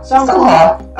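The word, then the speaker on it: Seonghwa: And I don't know if I pronounced his name right. – We can understand that. We can totally get it. Seongwa? Songhwa? – Songhwa. That's Amber's bias.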